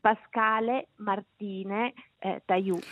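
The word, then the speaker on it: Pascale Marthine Tayou: Pensate che Pascal Martin Taiou, da giovanissimo, ha aggiunto una E alla fine del suo primo e del secondo nome. – Quello che volevo chiedere. – Si legge Pascale Martine Taiou.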